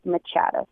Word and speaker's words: Machado: So pretty much what I'm most active on right now is Instagram, and my Instagram name is at Eva Machado, and Eva is just EVA, and Machado is actually spelled like Machado, so MACHADO.